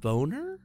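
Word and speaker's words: Bohn: Is it Bone or Bon? Who's the SC AD? I don't know how to say his last name. – That the first one was correct.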